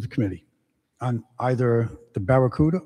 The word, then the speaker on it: Barracuda: So I had a flashback to my younger days when the vehicle, the automobile, came out and it was the Barracuda. And we used to pronounce it Bacaruda.